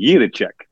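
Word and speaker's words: Jiříček: Is Alan saying it right? And or did we do an entire interview, with him last year pre-draft and pronounce his name wrong the entire time? We did. – Yeah. Now, if you really want to say it like a Czech, okay. – It would be Jiracek. Like you kind of roll that R a little bit.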